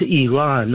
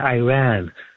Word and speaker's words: Iran: And then that guest of yours from Idaho, it's pronounced Iran, not Iran. Like, we don't say Italy.